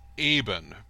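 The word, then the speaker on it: Eibon: And of all things, there's a song, technically it's pronounced Ebon, because it's inspired by the movie The Beyond by Lucio Fulci.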